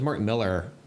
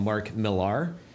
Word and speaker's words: Millar: His name is Mark Miller, but it's spelled M-I-L-L-A-R. – Yeah. – So a lot of people call him Mark Millar. But I've seen him in interviews where he's just like, just rhymes with Miller, spelled differently.